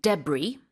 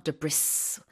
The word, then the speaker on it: debris: The pronunciation can also be debris. That's okay. But you don't pronounce the S. So it's not debris. It's debris or debris.